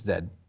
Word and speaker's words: Z: Not Z. – Did you say Z? It's Zed, just so you know.